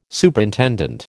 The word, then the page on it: Superintendent: American English